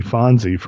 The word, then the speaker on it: Phonsie: Well, he's got to be because he has another son named Fonzie, but it's spelled Phonsie, Fonzie Franco.